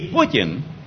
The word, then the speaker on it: Putin: Y Putin, que así se pronuncia, Puy es sendero, camino.